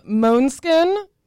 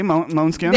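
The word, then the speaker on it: Måneskin: We have Moanskin. I checked. That's how you say it. – Okay, Moanskin.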